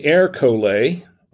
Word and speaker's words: Ercole: So this is Ercole, and it says Ercole. On it. That's how you pronounce it.